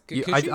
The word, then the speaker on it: Kikuchi: I mean, staying with with Rinko, uh, I'm gonna mispronounce it, Kikuchi. – I think that's Kikuchi.